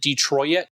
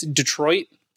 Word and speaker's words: Detroit: Apparently, it's a Canadian thing that he pronounces it Detroit. And I don't like it. So it's Detroit. Please stop saying it Detroit.